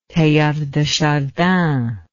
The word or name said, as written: Teilhard de Chardin